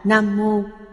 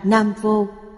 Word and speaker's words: Nam Mô: Chữ Nam Mô, thời cổ, hai chữ này được đọc là Nam Mô. Hiện thời, đọc thành Nam Vô. Chúng ta phải đọc theo âm thời cổ.